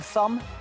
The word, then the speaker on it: thumb: What letter is not pronounced in the word thumb? – Rosie. – B.